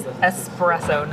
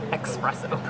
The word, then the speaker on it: espresso: Okay, but it is espresso, not expresso. – Correct. There's no X in espresso.